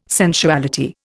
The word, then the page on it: sensuality: Indian